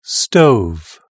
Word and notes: stove: British English

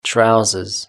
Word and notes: trousers: British English

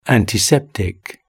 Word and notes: antiseptic: British English

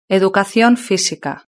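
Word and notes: educacion fisica: Spanish (Spain)

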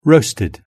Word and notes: roasted: British English

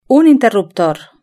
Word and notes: un interruptor: Spain Spanish

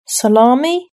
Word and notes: salami: British English

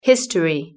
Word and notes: history: British English

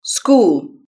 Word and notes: school: British English

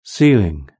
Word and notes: ceiling: British English

